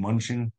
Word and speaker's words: Mnuchin: How do you say that name? Mnuchin? – Mnuchin. – Mnuchin. Okay, Mnuchin.